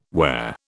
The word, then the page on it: whare: British English